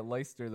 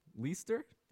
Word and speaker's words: Leicester: In the UK, specifically Leicester, that's spelled Leicester. I believe you say it Leicester, though. Leicester? Could be Leicester, appears the bones of none other than King Richard III were found after being buried and lost for over 500 years.